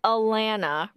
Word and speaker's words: Alana: Okay, her name is Alana, rhymes with banana.